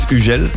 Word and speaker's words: Hugel: In French, it's Hugel. The English going like Hugo, you know, then I have the German going like Man Hugel.